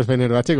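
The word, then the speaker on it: Fenerbahçe: Pero bueno, aún así, para que os hagáis una idea, este año el Fenerbahce, Sube un 144% en bolsa. – Mira, ha dicho Fenerbahce como si estuviese siguiendo las Champions de toda la vida. ¿Qué pronunciación más buena?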